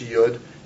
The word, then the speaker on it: Yod: And just in terms of pronunciation, it's Yod after the Hebrew letter Yod.